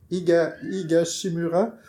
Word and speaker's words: Higashimura: Moi, je nommerais Akiko Higashimura. Ah, j'ai dit prononcer correct. Higashimura.